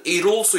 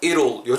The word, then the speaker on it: it'll: 그래서 대명사 it이랑 붙으면서 it will, it all, it all, it l l 우리 뜻을 이룰 수 있을까? 이룰 요 정도로 딱 발음해 주시면 비슷한 발음 날 것 같아요